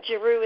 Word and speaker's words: Giroux: And Giroux is spelled G as in glorious, R-A.